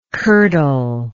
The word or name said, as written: curdler